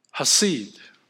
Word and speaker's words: chesed: Interesting, the Hebrew word for kindness is the word hased. And this word is spelled C-H-E-S-E-D. The C is silent, hased.